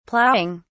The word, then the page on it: plowing: British English